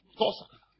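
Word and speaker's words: Xhosa: First thing, I'm a Tosa. Tosa. That's how you say it.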